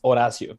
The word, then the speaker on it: Horacio: So my name is pronounced Horacio. In Spanish, the H is actually silent. Mm-hmm. So that's why everybody's like saying Horatio.